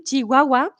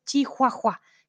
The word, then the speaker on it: chihuahua: Un chihuahua eh, lo, escribimos con H, pero no pronunciamos la H, no es chihuahua. No, no, no. Es como la ciudad.